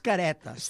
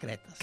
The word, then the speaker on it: Caretes: Sí, el nom exacte és Caretes. – Sí. – El que passa que, de la mateixa manera que de Carabassa, sí, hi ha gent que pronuncia Carbassa, clar, que... – doncs de Caretes, sí, habitualment se pronuncia Cretes.